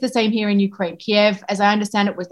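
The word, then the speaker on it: Kyiv: Then in 2014, when there is this invasion in Crimea, the Obama White House goes to a very particular pronunciation, Kiev. And I asked, what is that about? And they said, well, that's the Ukrainian preferred pronunciation. Kiev is the Russian preferred pronunciation.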